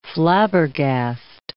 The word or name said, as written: flabbergast